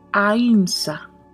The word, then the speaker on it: ahimsa: Esta palabra se pronuncia AIMSA. Es A-H-I-M-S-A. AIMSA. Muchos dicen AHIMSA.